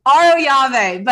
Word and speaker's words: Arroyave: Mrs. Teddy Mellencamp Arroyave. Am I saying that right? – Aroyave, but here's the thing.